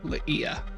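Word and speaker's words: Li'ia: Ah, Laia.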